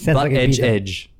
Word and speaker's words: Buttigieg: It's Butt-Edge Edge.